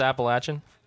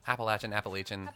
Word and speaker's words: Appalachian: I thought it was Appalachian. – Appalachian. I'm from here, motherfucker. – Hey, man. Okay. I just learned from locals that we say it wrong elsewhere.